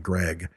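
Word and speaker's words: Greig: It's spelled Grieg, but they kept saying Greg.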